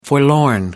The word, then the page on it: forlorn: American English